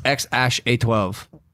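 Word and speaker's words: X Æ A-12: It's the kid's name is pronounced X Ash A 12, which is awesome.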